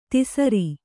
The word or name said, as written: ಧಸರಿ